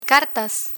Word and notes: cartas: spanish

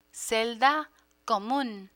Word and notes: celda comun: spanish

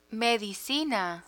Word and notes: medicina: Spanish

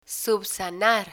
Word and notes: subsanar: spanish

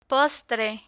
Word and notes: postre: Spanish

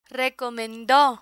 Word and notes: recomendo: Spanish

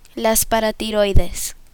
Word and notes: paratiroides: Spanish

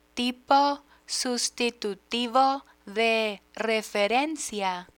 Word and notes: tipo sustitutivo de referencia: spanish

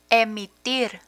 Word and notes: emitir: Spanish

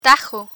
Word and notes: tajo: Spanish

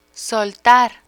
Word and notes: soltar: spanish

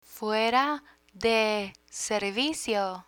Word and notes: fuera de servicio: Spanish

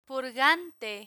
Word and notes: purgante: Spanish

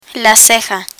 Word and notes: ceja: spanish

